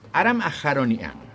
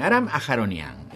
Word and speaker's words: Aram Aharonian: Aram Aharonian. Sí, lo dije bien. Aram Aharonian.